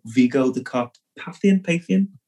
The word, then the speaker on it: Vigo the Carpathian: Before long, we find ourselves outside the New York Art Museum, and we get our first glimpse of the painting of Vigo the Carpathian. That's the pronunciation.